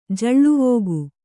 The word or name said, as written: ಜಳ್ಳುವೋಗು